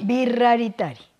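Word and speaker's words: Wixaritari: Los Huicholes o Guisaritari. Guisaritari. – Viraritari. – Viraritari, ya decía yo.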